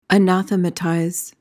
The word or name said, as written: anathematize